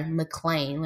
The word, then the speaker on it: McClain: Okay, so y'all, let's talk about Elijah McCain. Or, I'm sorry, McClain. Let me say his name right. Elijah McClain.